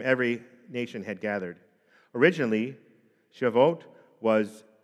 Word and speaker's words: Shavuot: Shavuot, right? Shavuot is S-H-A-V-U-O-T, is a name for this festival that marked not only this harvest time, but it also marked 50 days after Passover.